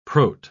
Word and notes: prot-: British English

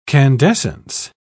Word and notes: candescence: British English

